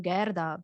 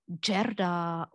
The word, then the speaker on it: Gerda: It is then Gerda or Gerde, or you know, you can spell it in different way, and it sounds not really like for my ears.